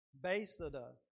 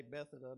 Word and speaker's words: Bethesda: And now Jesus is in Jerusalem at this pool called Bethesda. I had to write the pronunciation because I always want to say Bethesda, but it's Bethesda.